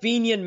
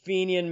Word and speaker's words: Fenian: The Bold Fenian Men. – Fenian Men, okay. Yep. I didn't want to say it wrong. – Yeah, the Bold Fenian Men.